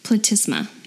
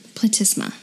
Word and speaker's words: platysma: Yeah, I just have to say the neck muscle that I'm thinking of is the platysma. Okay. Platysma. – Platysma. Yeah. All right. So I was probably saying it wrong.